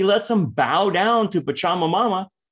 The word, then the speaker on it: Pachamama: It's Pachamama.